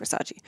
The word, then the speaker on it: Versace: He's like, it's Versace. Mm-hmm. It's pronounced Versace.